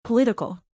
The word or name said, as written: political